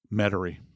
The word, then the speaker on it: Metairie: How did you pronounce that? Metairie. Metairie. Metairie, Louisiana. Yeah.